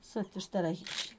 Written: zetiztaragizh

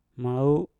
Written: mau

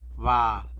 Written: bal

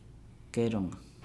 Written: geelonga

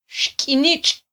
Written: xkyinitx

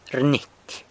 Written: rnyity

